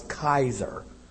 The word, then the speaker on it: Kaiser: That's how you would pronounce in Latin. You know, we say Caesar, they would have a hard K sound that would come out as Kaiser.